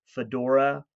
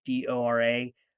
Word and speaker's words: Fedora: But it's G underscore Fedora, like the hat, Fedora underscore crypto, CRYPTO, like cryptocurrency.